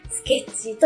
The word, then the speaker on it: 未完成品: スケッチ、未完成品と書いて、スケッチと読ます。